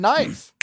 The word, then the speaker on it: knife: But think about the word knife. That K is silent.